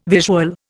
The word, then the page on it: visual: Indian